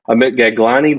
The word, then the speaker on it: Gaglani: Today, I am excited to chat with Amit Gaglani.